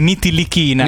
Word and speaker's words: Ntilikina: Allora, questo lo dobbiamo chiedere a loro. È la K che è più avanti. Nitilichina.